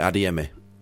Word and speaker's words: Adeyemi: Adeyemi.